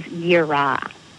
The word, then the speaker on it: yirah: The second word for fear that is used in the Old Testament is yirah, and that's spelled Y-I-R-A-H.